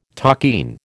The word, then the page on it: takin: American English